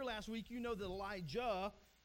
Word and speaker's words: Elijah: Elijah, okay, make sure you get those pronunciations right, because Elisha is different from Elijah.